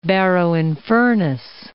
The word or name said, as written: Barrow-in-Furness